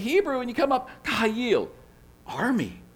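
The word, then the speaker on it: kahayil (חיל): The Hebrew word is kahayil. Kahayil. There you go. Kahayil. Two syllables.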